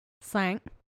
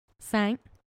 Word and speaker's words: cinq: Cinq. – What? – Cinq. – What? Spell that? – C-I-N-Q.